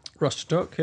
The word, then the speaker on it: Rostock: So is it Rostock? Was the first place that we were at Berlin last time, weren't we? So it's Rostock to start. – Rostock, yeah, that's how you pronounce that.